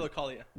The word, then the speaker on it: Philokalia: Philokalia.